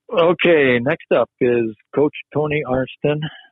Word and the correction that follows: Arnson: Oh, come on. You can't put him in yet. – I said his name wrong. – Arnson, yeah.